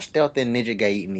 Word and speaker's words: Gaiden: It's not Gaiden. Yeah. I want to pronounce it. It's Ninja Gaiden.